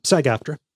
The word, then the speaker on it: SAG-AFTRA: First off, am I pronouncing that right? – Yeah, SAG-AFTRA.